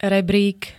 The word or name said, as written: rebrík